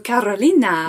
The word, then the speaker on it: Carolina: Oh, Carolina.